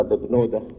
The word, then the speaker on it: ببنودة: رواها القديس الأنبا ببنودة. ببنودة هي النطق الصعيدي للاسم بافنوتي، يعني بتاع ربنا.